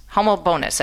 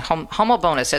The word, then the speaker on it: Homobonus: She's a president of Legatus, Washington, D.C., and she wrote a beautiful article on St. Honobus, Homobonus, that's his name, Homobonus, that's how you pronounce his name.